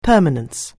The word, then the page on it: permanence: anglais